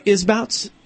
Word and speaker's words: Isbouts: Am I saying that right, Isbouts? – You're saying it correct.